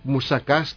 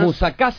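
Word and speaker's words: musakas (μουσακάς): Un muy conocido es este de musakas, que creo que todos conocen. – Sí, claro. – El famoso musakas, que es un plato con berenjenas. – ¿Musakas se dice? – Musakas. La pronunciación en griego.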